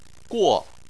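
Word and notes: guo: tone 4

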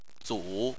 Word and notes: zu: 3

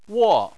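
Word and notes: wo: wo4; tone 4

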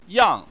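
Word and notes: yang: tone 4

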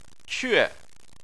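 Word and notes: que: tone 4

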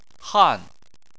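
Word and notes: han: tone 4

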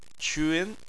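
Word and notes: qun: tone 2

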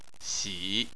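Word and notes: xi: xi3; tone 3

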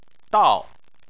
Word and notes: dao: 4

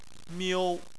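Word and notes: miu1: tone 1

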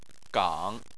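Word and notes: gang: tone 3

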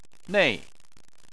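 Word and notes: nei: tone 4

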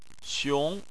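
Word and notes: xiong: xiong2; tone 2

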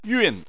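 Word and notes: yun: tone 4

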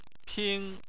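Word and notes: ping: tone 1

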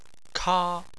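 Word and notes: ka: tone 1

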